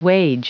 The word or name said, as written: wage